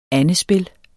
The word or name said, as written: andespil